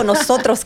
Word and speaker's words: Nosotros: Oh, Nosotros Cafe. – Nosotros. – Yes. Nosotros Cafe, which Nosotros. – Means us, all of us. – Okay. – We are all one, united. So yes, Nosotros Cafe in Banning, California, 3505 West Ramsey.